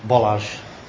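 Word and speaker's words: Balázs: Bolaj.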